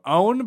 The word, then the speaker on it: Eoin: E-O-I-N is pronounced like own. So sort of like Owen, but with no hard like O-N on the end. So Owen Burke, shout out.